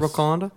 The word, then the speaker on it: Wakanda: The movie featuring Wakanda, right? Is that how it's pronounced? – Yes. – Wakanda.